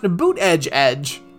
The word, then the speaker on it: Buttigieg: then Pete Buttigieg wrote a pronunciation that said his name should be pronounced Boot Edge Edge